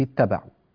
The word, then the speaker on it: اتبعوا: اتبعوا. كيف ننطق، كيف ننطق هذه الكلمة إذا أردنا البدء بها؟ نقول اتبعوا، اتبعوا من الذين اتبعوا،